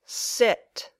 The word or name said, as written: sit